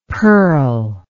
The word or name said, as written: perle